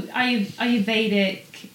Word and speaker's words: Ayurvedic: Am I saying that right? Yeah. Ayurvedic. – You can uh, say Ayurvedic or you can say Ayurvedic. Ayurvedic. But I always say Ayurvedic.